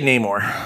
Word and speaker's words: Namor: Yeah, I just stumbled across some more Marvel news that there's rumors that they're going to change Namor. How do you say it? Do you say a Namor or Namor? – I say Namor.